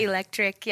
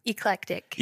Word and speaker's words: Eclectic: People say eclectic. Yeah, they something say like that. Electric. Yeah. – So how do you pronounce it again? – Eclectic.